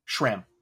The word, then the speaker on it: Schramm: Richard Schramm, am I saying that right? – Schramm. Rich Schramm.